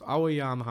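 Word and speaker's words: Ouwejan: dass ein OU, was man im Niederländischen benutzt, AU ausgesprochen wird und ein W, zumindest in dieser Konstellation, wie ein H gesprochen wird, also nicht betont, ja. Und jetzt wissen wir alle, dass dieser Mann Thomas Aujan heißt.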